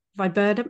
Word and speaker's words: Viburnum: the Salvia and Viburnum, have I said that right? Viburnum 2? – Yes.